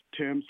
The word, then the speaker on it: Thames: Oh, it's pronounced Thames like the river in England, T-H-A-M-E-S, yes.